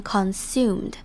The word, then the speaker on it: consumed: 接着我们来看 consumed 这个字，那要注意的地方是它的重音在第二个音节，也就是 su 这边 consumed。那它 con 这个地方 o 是念成的声音。 consume 这种感觉，那不要忘记它后面有一个 ed，在句子中也要清楚的把它念出来，要念成 consumed per day。